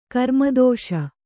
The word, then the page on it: ಕರ್ಮದೋಷ: karmadōṣa